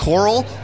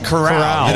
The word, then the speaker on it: Corral: I always want to pronounce here. – Coral, but it's Corral. – Corral, just like in Corral.